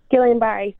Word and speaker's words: Guillain-Barré: Is it Guillain-Barré Association? – Guillain-Barré Syndrome.